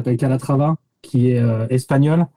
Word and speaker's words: Calatrava: Calatrava. C'est A-L-A-T-R-A-V-A. – Comme ça se prononce.